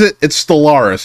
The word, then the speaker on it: Stellaris: It's Stellaris, am I saying that correctly? – Yeah, okay.